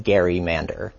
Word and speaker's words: gerrymander: and so it was called the Gerrymander. And today we pronounce that gerrymander, which is technically incorrect, but there's, no one ringing that bell.